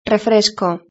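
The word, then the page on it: refresco: Spanish (Spain)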